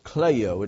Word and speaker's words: klaio: The Greek word for wept is pronounced kleio. It's spelled K-L-A-I-O.